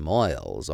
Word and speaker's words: moils: Why is one man a genius in his work or profession while the other man toils and moils all his life without doing or accomplishing anything worthwhile? Moils, that is a funny word, and it rhymes with toils.